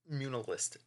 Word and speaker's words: Muunilinst: When Plagueis returns to Moonalist. It's spelled M-U-U-N-I-L-I-N-S-T, but the second N is silent.